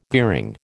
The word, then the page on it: gearing: American English